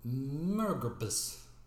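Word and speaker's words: MMORPG: Murgrabus. – Yeah, I believe that's the correct um, pronunciation, official pronunciation.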